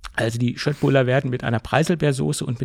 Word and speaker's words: Köttbullar: Ich habe extra recherchiert, ob es nicht Köttbullar ausgesprochen wird. Nein, es wird Köttbullar ausgesprochen. Das ist die richtige Aussprache.